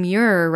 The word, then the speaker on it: Muir: And pitcher plant bogs are associated with a unique North American savanna that even John Muir referenced.